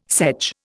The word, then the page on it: sedge: Indian